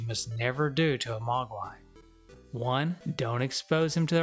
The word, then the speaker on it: Mogwai: However, Gizmo, being the curious Mogwai that he is that how you pronounce that? Mogwai? – Yeah, Mogwai? – I think so, yeah.